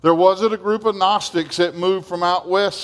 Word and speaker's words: Gnostics: There was a group within the church, in the early church, called the Gnostics, spelled with a silent G.